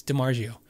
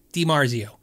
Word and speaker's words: DiMarzio: It's funny because when you think of, Eddie Van Halen and chorus pedals, he did it sparingly, but Larry DiMaggio DiMarzio. Everybody was like, you're saying it wrong. He doesn't seem to mind. So it's just probably my, if there's an accent, I think I have one. It's DiMarzio. DiMarzio. Larry